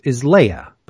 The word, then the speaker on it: Leigha: And her name is Leia. But it's spelled L-E-I-G-H-A.